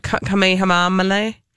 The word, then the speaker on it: Kamehameha: Kamanawanalea? – No, Kamehameha. Kamehameha. Kamehameha. Kama. – Kamehameha. – Thank you. That's it.